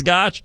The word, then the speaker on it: Goch: Always on X at Jason Gotch is my X handle, G-O-C-H, on the last name spelling, last part of that X handle. Sounds like there should be a T in there. There's no T. It's just the way it's spelled, and it's just the way it sounds. It sounds like Gotch because it is Gotch, but there is no T. So at Jason Gotch, G-O-C-H, on the last name spelling for my X handle.